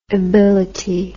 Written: ibility

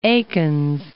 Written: Eakins

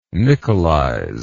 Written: nickelize

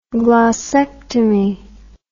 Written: glossectomy

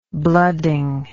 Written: blooding

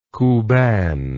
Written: kuban

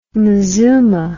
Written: mezuma

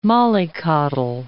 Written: mollycoddle

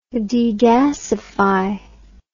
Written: degasify

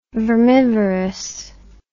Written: vermivorous

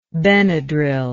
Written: benadryl

